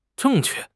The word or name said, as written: zheng4 que4